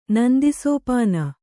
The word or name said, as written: ನಂದಿಸೋಪಾನ